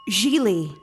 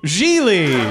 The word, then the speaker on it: Gigli: Gigli. – Gigli.